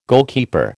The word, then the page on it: goalkeeper: American English